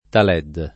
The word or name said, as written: taled